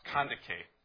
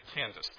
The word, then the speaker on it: Kandake: Now, I understand this is pronounced Kandake. I would say Candace there, but one of my research materials was saying that's Kandake.